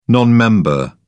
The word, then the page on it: non-member: British English